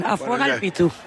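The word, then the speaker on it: Afuega'l Pitu: Sí, lo has dicho bien, Afuegal Pitu.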